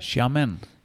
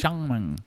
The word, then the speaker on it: Xiamen: So in the coastal city of, I don't know how to pronounce it, Xiamen, Xiamen. Do you know how to pronounce that? – Xiamen. – Xiamen? – Xiamen.